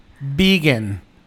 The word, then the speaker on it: Vegyn: Bueno, entonces vamos a poner esta canción de Vegan. Vegan. Se escribe V-E-G-Y-N.